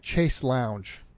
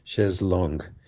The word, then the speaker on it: chaise longue: In French, it would be chaise longue. Chaise. A-I is pronounced eh. Chaise longue.